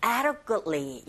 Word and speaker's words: adequately: Small basket, filled, 그리고 네, adequately. 이렇게 발음하셔야 되는데, 첫 번째 강세를 꽝 주시고, ada, 여기는 d 사운드가 모음 사이에서 르처럼 들려서 ada, ada. 그리고 adequately가 아니라 역시 t 사운드가 거의 안 들리죠.